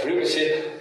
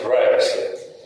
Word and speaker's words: privacy: И вторая вещь, как перевести на русский язык английское слово, которое англичане произносят как «privacy», а американцы «privacy».